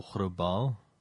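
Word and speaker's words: Grauballe: The Grobol Man, or Grobal, I have to pronounce it like that specifically, because this is also in the Germanic sphere of things.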